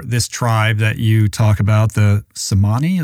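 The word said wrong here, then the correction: Tsimane: is that how you say it? Yeah, Chimane or you look at the blue zones,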